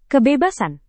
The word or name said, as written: kebebasan